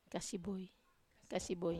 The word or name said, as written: kasiboi